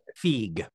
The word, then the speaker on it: Feig: I don't know if he pronounces it Feig or Feig, F-E-I-G. – Feig. – Feig. Okay. – It is Feig, yes.